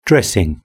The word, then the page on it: dressing: British English